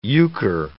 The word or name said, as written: euchre